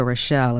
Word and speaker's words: Rochelle: My name, Rita Rochelle, is spelled R-I-T-A. Rochelle is R-O-C-H-E-L-L-E.